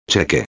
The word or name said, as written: cheque